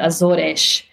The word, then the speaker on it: Azores: Actually, I'm saying the Azores, but people are going to call me out. It's actually the Azores.